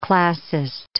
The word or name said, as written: classist